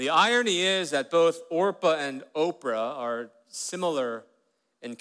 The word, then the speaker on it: Orpah: Her original name was Orpah. Did you know that? But people mispronounced her name on a regular basis. She was called Oprah all the time. Like, hey, Oprah. Oh, my name's Orpah.